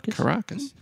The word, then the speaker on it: Caracas: Caracas.